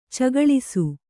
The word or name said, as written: ಚಗಳಿಸು